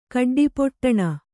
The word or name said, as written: ಕಡ್ಡಿಪೊಟ್ಟಣ